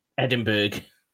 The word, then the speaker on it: Edinburgh: Oh, God, the amount of people that actually pronounce Edinburgh wrong is ridiculous. – Edinburgh. – And Glasgow. – Glasgow. Sorry to our American listeners. We're not mocking you. We're just wanting to make sure that you're pronouncing it correctly.